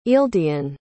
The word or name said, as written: ealdian